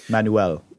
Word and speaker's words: Manuel: I might have put an extra syllable in there. Manuel. – Manuel. – It's not an instruction manual.